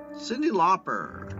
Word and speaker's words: Cyndi Lauper: Say her name right. Okay. Cyndi Lauper.